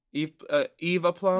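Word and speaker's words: Eve Plumb: How do you say that? – A plum? – A plum, yeah. Eve a plum? – I think you said it right.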